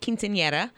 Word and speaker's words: Quinceañera: I actually called my daughter last night and I was like, let's make sure I can say it. Quinceañera.